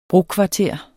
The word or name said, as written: brokvarter